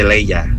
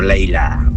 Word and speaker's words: Leia: Y se dice Leia, no Leila.